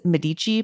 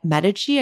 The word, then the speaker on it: Medici: Like, I used to think it was Medici, by the way. I don't know why I thought that, but Medici, I feel like, is the Italian pronunciation.